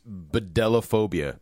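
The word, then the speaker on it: bdellophobia: And the phobia is bedellophobia. B-D-E-L-L-O. Bedellophobia. – I hate that. BD. How do you say a BD? – Bedell? Bedell.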